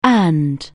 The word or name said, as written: ancillary